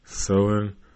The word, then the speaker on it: Samhain: Halloween's origins date back to an ancient Celtic hunky shit festival of Samhain, pronounced Samhain,